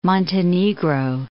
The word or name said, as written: Montenegro